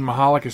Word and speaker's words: Michalec: If you're new to the show, Sharon insists that you know that the name Mahalik is spelled M-I-C-H-A-L-E-C.